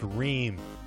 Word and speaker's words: Ream: My last name, it's pronounced Ream. You said meme? – No, I mean, do you like memes?